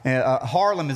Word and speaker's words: Haarlem: Haarlem is actually how it's pronounced, outside of Amsterdam.